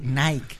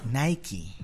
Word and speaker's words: Nike: No se dice Nike. – ¿No? – Se dice Nike.